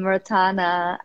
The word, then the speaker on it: Rotana: Your Instagram, I am Rotana, and I'm. – Yes. – I know I don't pronounce it. – No, you do, Rotana.